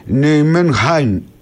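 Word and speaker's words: Nijmegen: Η Ολλανδική όμως λέει προφορά είναι Νιουμενχάιν, είναι κάπως βαθύ το χι, δεν μπορώ να το πετύχω.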